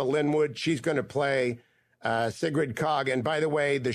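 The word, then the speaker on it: Sigrid Kaag: And I mean Sigrid Kog.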